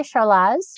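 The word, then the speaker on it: Charlas: And this year again, there is a, I guess it's pronounced Charles track going again, right? – Yeah. I've heard people say Charles.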